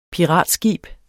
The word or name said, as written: piratskib